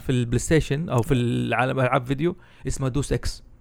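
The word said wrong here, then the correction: Deus Ex: ايوه ديوس اكس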